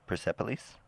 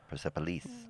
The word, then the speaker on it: Persepolis: Persepolis? – Yeah, I say Persepolis. – Persepolis.